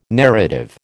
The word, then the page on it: narrative: American English